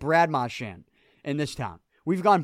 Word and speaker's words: Marchand: He came out and said, no, it's Marchand.